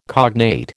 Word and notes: cognate: American English

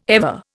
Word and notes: ever: Indian

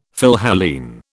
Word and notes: Philhellene: British English

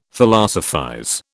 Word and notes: Philosophize: British English

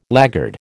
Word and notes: laggard: American English

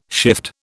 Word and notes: shift: British English

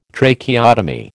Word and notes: Tracheotomy: American English

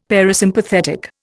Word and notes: parasympathetic: Indian

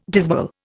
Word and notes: dismal: Indian

